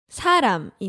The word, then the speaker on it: 사람: It sounds similar to the word 사랑, right? – Oh, yeah, right. 사랑. – Yeah, if you say 사랑 with the 응 sound at the end, it is love. – Yeah, that's love. – Yeah, and if you say 사람 with an M sound at the end, that is? – A person.